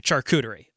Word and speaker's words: charcuterie: All right, well, before we start, first off, Tony Cristo on Twitter wanted to know how you say charcuterie. That's how you say it, charcuterie.